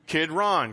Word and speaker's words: Kidron: Now, how you remember this word Kidron is you think of Ron when he was a kid. Kid Ron, because I was having a hard time pronouncing it. Rosalie said, just think of Ron as a kid, and boom, I haven't been saying it wrong since.